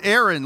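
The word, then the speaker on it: Erin (ἔριν): The strife is the Greek word Erin, like the girl's name, E-R-I-N is how you would spell it in English.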